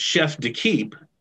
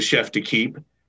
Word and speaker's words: Chef d'Equipe: He was, and I later learned it's pronounced Chef d'Equipe for the U.S. team, mm-hmm.